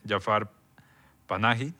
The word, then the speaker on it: Jafar Panahi: Tenemos la más reciente película de Jafar Panahi, no sé si lo dije bien. – Sí, Tres rostros.